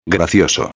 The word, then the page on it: gracioso: espanhol